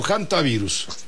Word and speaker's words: hantavirus: ¿Hantavirus o hantavirus? – Se dice hantavirus, aunque se escribe con H. Pues la cuestión anglosajona que hacen que la H suene como J. Pero es hantavirus.